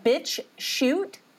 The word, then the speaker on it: BitChute: because I'm allowed to on BitChute. I'm allowed to play music. But now I still have. – How do you say that? BitChute? – Yeah, BitChute. BitChute.